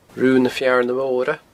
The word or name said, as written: rubhanafearnamora